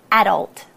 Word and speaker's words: adult: The emphasis is on the a. Adult.